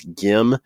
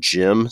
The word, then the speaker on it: Gim: I've heard a lot of people, especially that call curling games on the internet, pronounce her last name as Gim. But then I saw a WCF interview with her where she said Jim. So she says Jim, so I'm saying Jim.